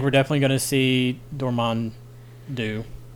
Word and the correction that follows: Dormammu: Come on, you guys, Dormammu.